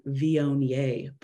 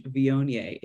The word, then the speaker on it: Viognier: So I never heard of the white wine Viognier before. It looks like Wagner, like it's spelled weird, but it's a really nice white wine.